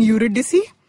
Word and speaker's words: Eurydice: So it's about this Greek mythology story of Orpheus and Eurydice. – Okay. – Mm. I think I pronounced that name. Eurydice. There we go.